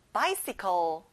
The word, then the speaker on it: bicycle: 앞에 by에 크게 말해볼까요? bicycle. That's right.